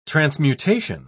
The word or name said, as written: transmutation